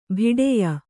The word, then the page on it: ಬಿಡೆಯ: biḍeya; kannada